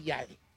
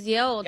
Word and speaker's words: Yale: No se dice, se dice Yale. – Es Yale.